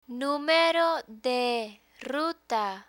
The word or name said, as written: numero de ruta